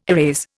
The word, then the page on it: erase: Indian